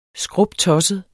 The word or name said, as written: skruptosset